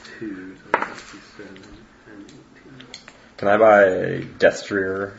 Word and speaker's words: destrier: Destrier. – Destrier.